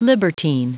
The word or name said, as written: libertine